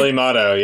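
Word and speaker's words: Peli Motto: Is it Pelimoto? Am I saying that right? – Pelimoto, yes, yes.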